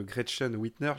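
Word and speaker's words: Gretchen Whitmer: Si jamais il ne devait, pas y avoir, devait pas Biden, il y a aussi le nom de Gretchen Whitner. J'espère que je prononce bien. Oui, c'est ça.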